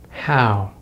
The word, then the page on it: how: American English